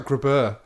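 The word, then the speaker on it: Grabher: Grab Her. – Oh, God. That's his name? – Grab Her. But I think it's pronounced like Grabber. – Grabber. – But it's G-R-A-B-H-E-R. Grab Her.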